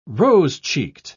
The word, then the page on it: rose-cheeked: British English